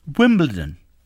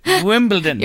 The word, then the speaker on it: Wimbledon: But I just hope that all sports announcers and people talking about the tournament in London pronounce it as Wimbledon. Because a lot of people, especially in this country, say, and the tennis today at Wimbledon. T-O-N. It's one of my bugbears in life.